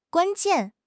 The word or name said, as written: guan1 jian4